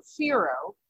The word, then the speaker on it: Whiro: People freak out a little because it's spelled W-H-I-R-O, but it is pronounced like fear, Firo.